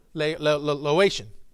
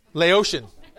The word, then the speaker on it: Laotian: How do you say it? Laotian. Laotian. Like an ocean.